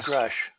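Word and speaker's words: Grusch: And and according to Brass Check TV, anyway, I just wanted to very strongly second what Steve Bassett said about, is it Grush? – Yeah, David Grush. – Grush, I'm pronouncing that correctly.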